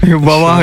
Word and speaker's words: Wah Wah: Wawa, ik weet niet hoe het uitspreken op zijn Engels. – Kan je dat nog één keer herhalen? – W-A-H, W-A-H. En ik noem gewoon Wawa. – Wawa, ja, heel goed.